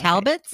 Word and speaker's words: Talbots: It's Talbots, and I've just been saying it wrong.